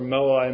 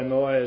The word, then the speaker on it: Moai: You can look up Easter Island or Moai. Moai is spelled M-O-A-I.